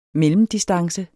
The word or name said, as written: mellemdistance